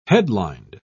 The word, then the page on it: headlined: British English